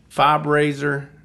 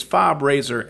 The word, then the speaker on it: FOB Rasor: Fob Razor. And it's Fob Razor, F-O-B. Razor is spelled with an S. It's not a Z. It's an S.